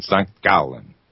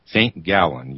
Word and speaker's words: St. Gallen: That's how they pronounce it in German or Swiss German, St. Gallen. St. Gallen, you would say. G-A-L-L-E-N.